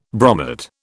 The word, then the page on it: bromate: British English